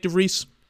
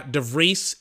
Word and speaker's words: de Vries: Nyck de Vries. That's going to be interesting to say his name because it sounds like Debris, but it's De Vries. I think that's how you say his last name.